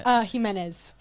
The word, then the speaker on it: Jimenez: Jimenez. – Jimenez. Okay. So the J is an H.